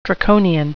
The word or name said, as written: draconian